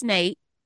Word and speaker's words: Nat: So Nate Love, and you'll see his name is spelled N-A-T, but it is pronounced Nate.